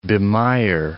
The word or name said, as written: bemire